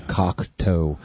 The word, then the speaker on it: Cocteau: No, it's spelled C-O-C-T-E-A-U, Cocteau Twins.